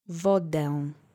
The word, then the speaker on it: wodę: Accent is on the first syllable. Wodę.